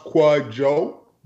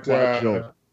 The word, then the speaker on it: Kwadwo: The first name is pronounced Kwadjo, but... Kwadjo. But the DW is like a J.